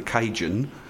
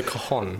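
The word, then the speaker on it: cajón: Now, you sometimes play what I thought was called the Cajun, but it's actually pronounced the Cajun, I believe. – The Cajon. – The Cajon, yeah.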